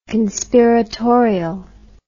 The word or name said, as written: conspiratorial